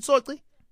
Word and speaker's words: Soci: Muzi teaming up with Lukanyo Soci. Soci? Is it Soci? S-O-C-I.